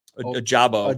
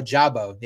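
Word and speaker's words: Ojabo: David, is it how do you say it? – Ajabo. – Ajabo. Yeah, yep.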